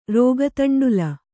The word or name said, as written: ರೋಗತಂಡುಲ